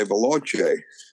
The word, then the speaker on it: Veloce: Well, how's that pronounced? Veloce? – Well, I would say Veloce. – Aha. You've got that Italian sound.